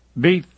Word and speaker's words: Deeth: "Well, Mr. Death, Deeth, remember, it's a long E.